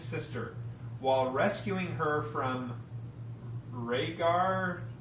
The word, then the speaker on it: Rhaegar: So Rhaegar, I guess Rhaegar, I'm fine with that pronunciation, they killed him and took over the kingdom from him.